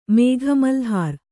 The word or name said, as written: ಮೇಘಮಲ್ಹಾರ್